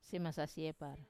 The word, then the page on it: semasasiepar: wamesa